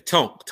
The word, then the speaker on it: tonk: Conk. I don't know nothing about that. – Okay, tonk. – Tonk. Yeah. See, look at that. I'm gonna say it right.